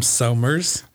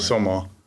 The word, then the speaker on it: Sommer: I say summer, but the Germans say right. Soma.